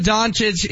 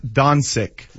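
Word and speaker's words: Dončić: It's actually pronounced Doncic, Luka Doncic.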